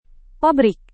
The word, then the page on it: pabrik: Indonesian